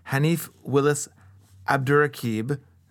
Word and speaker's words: Hanif Willis Abdurraqib: We'll also talk about Hanif Willis Abdurraqib. Did I say that right? – Yep.